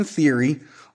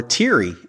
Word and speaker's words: Theory: But also on that episode of Raw, we had Austin Theory, or Teary, as Kevin Patrick on commentary likes to pronounce his name, getting a win over Shelton Benjamin in a one-on-one match early in the show.